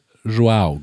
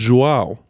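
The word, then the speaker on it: João: Und der andere wurde Joao ausgesprochen, das stimmt. Joao. Joao. Wie Joao Cancelo, der neue Außenverteidiger vom FC Bayern.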